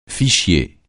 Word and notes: fichier: francais

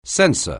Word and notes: censor: anglais

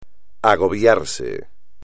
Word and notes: agobiarse: espagnol

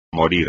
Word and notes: morir: espagnol

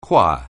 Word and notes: choir: anglais

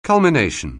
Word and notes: culmination: anglais

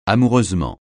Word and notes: amoureusement: francais

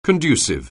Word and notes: conducive: anglais

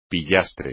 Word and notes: pillastre: espagnol

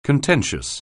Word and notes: contentious: anglais

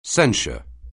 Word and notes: censure: anglais

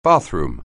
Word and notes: bathroom: anglais

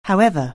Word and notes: however: anglais

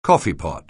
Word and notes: coffeepot: anglais

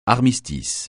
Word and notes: armistice: francais

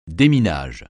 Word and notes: déminage: français

